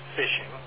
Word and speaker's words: phishing: For those not aware, the term phishing is pronounced phishing, but spelled with a P-H.